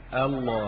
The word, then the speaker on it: الله: ويعني في طريقة النطق بيّن أهل اللسان أنه عندما تحذف الهمزة بين اللامين تخفيفا وتضغم اللامان معا يكون النطق الله، هي أصلها الإله، الإله.